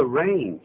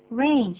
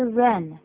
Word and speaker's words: rain: 这个 rain 就是雨，它是这么拼的呢。 – R-A-I-N, rain, 请注意发音哦，是 rain, 而不是 run。